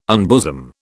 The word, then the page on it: unbosom: British English